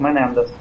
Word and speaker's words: Менендес: Ну, как у всех испанцев, на предпоследний слог Менендес.